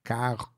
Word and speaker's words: carro: like C-A-R-R-O. – So it's like Spanish, but with an extra R, but then the R's aren't pronounced. – Yeah, but the way we do is stretch the R like carro.